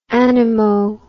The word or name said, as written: anemo